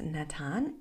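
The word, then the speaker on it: natan (נתן): It's nun, taf, nun. And it's spelled natan. That is what the word is, says, natan.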